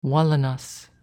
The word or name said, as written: Wallenas